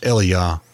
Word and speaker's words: Elijah: and that's why I do pronounce yeah. Elijah that we say in English Eliyah.